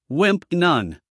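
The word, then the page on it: wimp: uk /wɪmp/; en-us /wɪmp/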